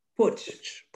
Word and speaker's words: putsch: And the pronunciation is PUTSCH.